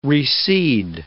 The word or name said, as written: reseed